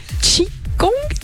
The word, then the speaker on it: Qigong: En fait, déjà, premièrement, tu es justement atteint de la mucoviscidose et tu es également professeur de Qigong.